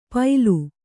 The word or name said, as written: ಪೈಲು